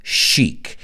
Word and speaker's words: chic: Yo sé que se escribe chic, pero nosotros decimos chic.